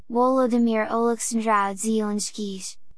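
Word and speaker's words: Volodymyr Oleksandrovich Zelensky (Володимир Олександрович Зеленський): Volodymyr Oleksandrovich Zelensky, Ukrainian, pronounced Volodymyr Oleksandrovich Zelensky,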